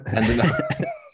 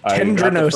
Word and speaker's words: tendinosis: Tendinosis. Tendinosis. First syllable for sure. Um, sounds like sounds like dinner.